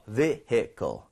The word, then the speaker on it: vehicle: 근데 여기서 중요한 건 H 발음을 꼭 해달라라고 하는 겁니다. Okay? Vehicle 이게 아니고요. Vehicle 이런 식으로 두 번 드릴게요.